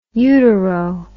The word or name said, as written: utero